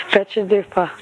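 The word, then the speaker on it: Betchadupa: Yeah, no, you're pronouncing it right. That's how I would have pronounced it. Betcha Dupa.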